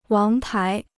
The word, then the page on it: 王牌: wáng pái